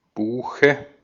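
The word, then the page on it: bok: IPA: /buːk/; Swedish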